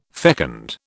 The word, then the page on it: fecund: British English